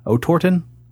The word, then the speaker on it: Otorten: How would you pronounce O-T-O-R-T-E-N? Otorten? – Yeah, we're going to go with it.